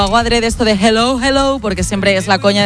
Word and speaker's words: hello: Y la segunda, dile a Elena que su dicción en castellano es la más perfecta que conozco, porque cuando diga hello, que no pronuncie la J, que no es hello, que es hello, que la aspire la J y no pronuncie la J potente castellana.